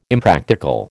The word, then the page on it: Impractical: American English